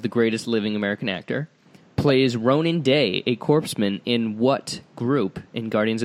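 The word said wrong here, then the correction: corpsman: It's pronounced Corman.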